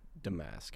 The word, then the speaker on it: Domask: Damask. – Damask. – I think it's more like a soft O.